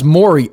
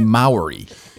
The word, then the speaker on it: Maury: So you pronounce Maury, Maury. You see that, you see Maori.